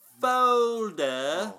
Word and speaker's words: Folder: Folder. – Oh, music to my ears. – You don't hit the R. That's the accent rule. – The silent R.